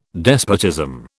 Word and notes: despotism: British English

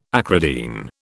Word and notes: acridine: British English

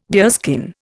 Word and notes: deerskin: Indian

